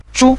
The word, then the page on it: zhu: zhu5